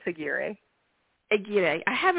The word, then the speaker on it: Aguirre: Because there's so if you guys go to her Instagram, it's Ann underscore Aguirre underscore author. Uh, uh, I'm sorry, Aguirre. Aguirre. Um, no, it's Aguirre. – Aguirre.